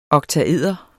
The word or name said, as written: oktaeder